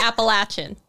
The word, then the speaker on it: Appalachian: So we're going to start with, can you confirm or deny that it should be pronounced Appalachian versus Appalachian? – I can strongly confirm that. – That it should be Appalachian. That it should be Appalachian. – I can so strongly confirm that, yes.